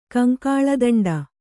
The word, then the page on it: ಕಂಕಾಳದಂಡ: kannada